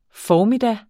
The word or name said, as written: formiddag